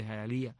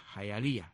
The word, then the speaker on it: Hialeah: palabra indígena que se escribe Hialeah, Jayalía, que la vi por primera vez en aquel sobrecito de espejuelito. ¿Qué cosa es Hialeah? Oh, Hialeah es Jayalía.